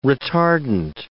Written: retardant

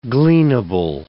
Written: gleanable

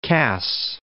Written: Cass

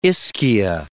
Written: Ischia

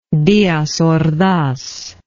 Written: diaz ordaz

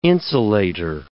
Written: insulator

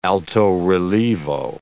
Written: alto-relievo

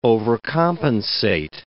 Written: overcompensate

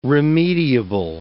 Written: remediable

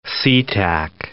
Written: Sea Tac

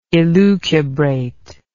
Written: elucubrate

